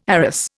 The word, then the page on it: arras: Indian